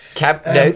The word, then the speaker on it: Camp Nou: Camp Nou, thank you very much.